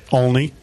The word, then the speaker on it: Olney: Olney.